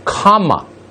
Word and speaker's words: Kamala: It is pronounced comma, not semicolon, comma, like the punctuation mark, a la Harris.